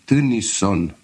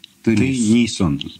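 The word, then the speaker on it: Тыниссон: Простите, как Ян? – Ян Теннисон. – И по-русски пишется Т-Ы. – Т-Ы, да. – Теннисон. – Теннисон, да.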